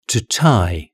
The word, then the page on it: to tie: British English